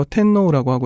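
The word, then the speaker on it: 텐노우라고 (てんのう): 천황을 뭐라고 부를 것인가, 천황이라고 부를 것인가, 일왕이라고 부를 것인가, 혹은 일본식 발음으로 텐노우라고 하고요. 그것을 한국어로 적으면은 첫 번째 자음이 약해지기 때문에 덴노우라고 적게 되죠.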